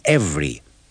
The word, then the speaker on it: every: In these sentences and dialogues, don't pronounce the second E in every, and listen carefully to the sound of the first vowel in either and neither.